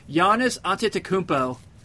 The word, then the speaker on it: Giannis Antetokounmpo: Giannis Antetokounmpo. That's not enough syllables, is it? – That's right. – It is right.